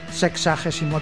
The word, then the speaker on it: sexagésimo: Sexuagésimo tercer programa. – ¿Sexagésimo tercer programa? – Sí.